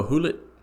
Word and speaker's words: Gullit: Ruud Gullit. – Oh, Hulit. – Hulit. – Yes.